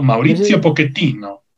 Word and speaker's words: Pochettino: No, Mauricio Pochettino. Ah, chinga. – Es que no es lo mismo Pochettino que Pochettino, o sea, son cuestiones de pronunciación y en realidad así se pronuncia.